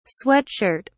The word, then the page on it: sweat shirt: British English